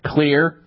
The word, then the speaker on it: Xlear: And so you need to keep your nose clean with a therapeutic nasal spray called Clear, X-L-E-A-R. That's how you spell it, X-L-E-A-R, nasal spray.